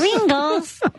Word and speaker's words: Kringles: Kringles. – Of course. – That rhymes with Pringles.